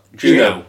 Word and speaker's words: Juneau: Juno. – J-U-N. – Like the film. – Like the film. – E-A-U.